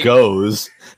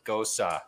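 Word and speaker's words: Gose: It's called Goes. – No, it's not, dude. Google it. It's G. It's like you pronounce it like nose. – Okay. – But with a G, and then you at the end. It's a Gosa.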